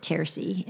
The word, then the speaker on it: Terces: Yeah, so you pronounce it Terces. And again, how do I know how to pronounce it? Because that's what I heard.